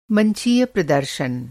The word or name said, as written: मंचीय